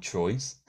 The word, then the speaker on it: Troyes: So he was talking about going to France, going to a place called Troyes, which is spelled T-R-O-Y-E-S. I, being a non-French speaker, thought it was called Troyes. but he said it's called Troyes.